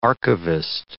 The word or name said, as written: archivist